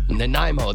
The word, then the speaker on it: Nanaimo: Nanomi bars or Nanaimo, that's it.